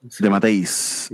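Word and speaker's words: DeMatteis: Sí, y esta historia está escrita por, ¿cómo se dice? De Matisse. – De Matéis. El guion es de Matéis, pero dice que supuestamente el plot es de Tom DeFalco.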